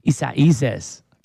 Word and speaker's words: Isaias: Yeah, I had to learn how to say that. I went to YouTube and said, how do you pronounce the hurricane? It gave me in detail, Isaias.